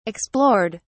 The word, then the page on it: explored: British English